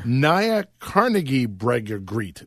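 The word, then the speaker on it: Nya Carnegiebryggeriet: I hope I'm saying this right. The Nya Carnebrygget. Right, that's come on, IKEA boy. – Pretty sure that's a dresser. – Nya Carnebrygget. There we go. That's a brewery. They teamed up with. – There we go. Like you said it right.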